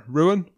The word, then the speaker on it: Rouen: But going down to Pro D2 and Rouen, is that how you say it? Rouen? – Rouen, yeah.